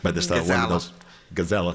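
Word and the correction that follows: Gazela: Okay. – Gazella.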